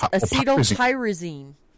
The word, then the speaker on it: acetylpyrazine: It's acetylpyrazine.